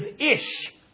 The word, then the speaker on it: ish: And if you were to do a word study, you would find out that the word for man, the pronunciation in Hebrew is ish.